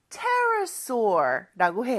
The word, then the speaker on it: pterosaur: Pterosaur라고 해요. 근데 왜 P 발음을 안 할까요? P는 묵음입니다. 그래서 스펠링은 P로 시작하지만 발음은 pterosaur라고 해요.